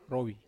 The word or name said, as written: rowi